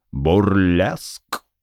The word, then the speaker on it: бурлеск: И, перебрав тысячи всевозможных вариантов, решил остановиться на простом и лаконичном слове «бурлеск». Вот только с ударением на первый слог.